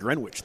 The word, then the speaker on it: Greenwich: What took you to Baylor out of, like, Greenwich, though? – Well, first of all, say it right. It's Greenwich.